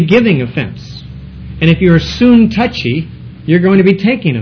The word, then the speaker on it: Syntyche: Actually, Syntyche can be pronounced a little bit more like soon touchy, soon tucky.